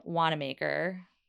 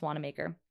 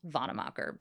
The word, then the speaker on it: Wannamaker: Play on my last name, Wannamaker. Looks like Wannamacher, but it's pronounced Wannamaker. It's actually pronounced Vonnemacher, but we're not. We have a low bar here.